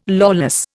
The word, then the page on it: lawless: Indian